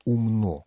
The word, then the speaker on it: умно: Кроме того, орфоэпические словари русского языка все в один голос говорят, что вариант «умно» вместо «умно» не рекомендуется, он недопустим. Норма только «умно».